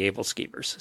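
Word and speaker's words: æbleskiver: Apparently, I got credit, though, from our Danish listeners saying it correctly, able skeevers.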